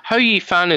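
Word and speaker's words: Hou Yifan: Ho Yifan is the one that everyone seems to get wrong, possibly because Ho has a pretty nasty connotation in American English, but it's the correct pronunciation, so there's not much you can do about it.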